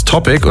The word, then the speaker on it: Topic: Und dann hat er sich gedacht, halt stopp, wenn ich es Englisch ausspreche, ist es Topic und das ist der geilste Name überhaupt.